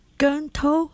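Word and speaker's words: Keung To: And I think, I'm still trying to say it right, but Gunto, that's spelled K-E-U-N-G. And T-O is this. So his surname is Gun, right? His first name is To? – Yeah, first name is To.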